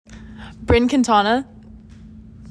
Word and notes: Brynn Quintana: BRIN KEEN tah nah

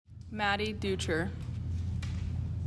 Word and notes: Maddie Deucher: MAD dee DOOCH er

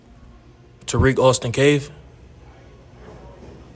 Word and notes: Tirek Austin-Cave: tah REEK awe STIN CAVE

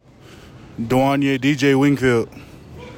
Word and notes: DJ Wingfield: de WON yay DEE JAY WING field

